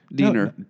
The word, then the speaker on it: Diener: Diner. Diener.